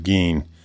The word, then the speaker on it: Gein: some say Gein. He said, I have always said Gein.